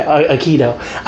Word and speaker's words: Aikido: People can pronounce it a little different. – Spell it. – A-I-K-I-D-O. – Okay, Aikido. I was like, Ikea? Aikido, thank you.